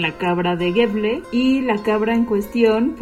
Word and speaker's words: Gävle: pero creo que es Gävle, se escribe como Gafle a con diéresis, pero creo que es Gävle,